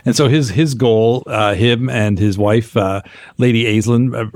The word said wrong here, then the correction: Aislinn: Ashlyn, I should say, that's how you pronounce it, but played by Billie Piper from Doctor Who, who uh-huh.